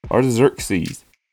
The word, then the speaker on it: Artaxerxes: According to Wikipedia, it is pronounced Artaxerxes.